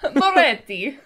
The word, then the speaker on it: Moretti: I'm sorry, I'm going to ask you to repronounce that in the correct Italian accent. – Moretti.